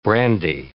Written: brandy

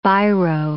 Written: biro

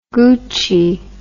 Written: gucci

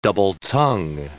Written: double-tongue

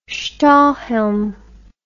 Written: stahlhelm

